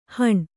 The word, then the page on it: ಹುಣಿ: kannada